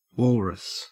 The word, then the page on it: walrus: Australian English